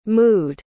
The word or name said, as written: mooed